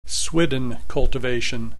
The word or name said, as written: swidden cultivation